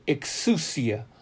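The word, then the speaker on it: exousia (ἐξουσία): In the concordance, it's just simply the Greek word 1849, and it means, or it is pronounced, exousia.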